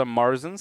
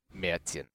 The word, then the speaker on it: Märzen: It's pronounced Märzen. – Märzen. Yeah. – Otherwise, it sounds like you're just mispronouncing aliens. Yes, Märzen. Yes, because the Germans like to make things sound extra weird. – They're called umlauts.